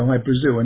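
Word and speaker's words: Hy-Brasil: People pronounce it Hi Brazil, and it's yeah, Brazil. Okay. – yeah. But it's Hi Brazil because that's how the Irish would pronounce it, because it's an okay.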